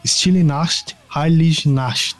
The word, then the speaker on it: Stille Nacht, Heilige Nacht: De forma sublime no seu ouvido, que é a Stille Nacht, Heilige Nacht.